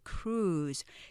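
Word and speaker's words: cruise: 크루즈 이렇게 삼음절로 하지만 이렇게 하시면 크루즈 못 가실지도 몰라요. 이거의 음절은 이름절이에요. 크루즈 길게 하나의 음절로 자 한글처럼 사각 표시한 건 이름절이라는 의미겠죠. 그렇게 크루즈 이름절로 발음하시면 되고요.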